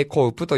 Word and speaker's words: AKOPE: A-K-O-P-E でございます。ね、それで A コウプと読むんですけれども、